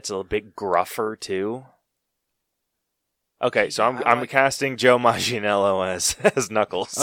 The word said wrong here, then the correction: Manganiello: Really quickly, I want to make sure that we're pronouncing his name right. – Yeah, his is a tough one. – Yeah, Joe Maginello. Is that how you say it? – However you say it. – Yeah, I'm going with Joe Manganiello.